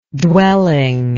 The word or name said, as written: dwelling